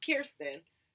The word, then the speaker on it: Kirsten: My name's Kirsten. K-I-R-S-T-E-N.